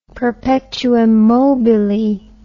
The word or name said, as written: perpetuum mobile